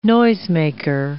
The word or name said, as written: noisemaker